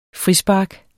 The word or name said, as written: frispark